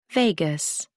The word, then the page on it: vagus: American English